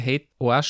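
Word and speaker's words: HATEOAS: Und den dritten Hotelstern, den kriegst du dann auch noch, wenn es diese Discoverability mit Hate OAS, wenn diese auch noch unterstützt wird. – Ah, du hast meine Aussprache Hate OAS schon übernommen.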